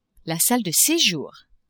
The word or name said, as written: salle de sejour